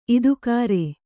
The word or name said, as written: ಇದುಕಾರೆ